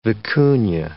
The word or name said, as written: vicuna